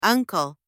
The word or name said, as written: uncle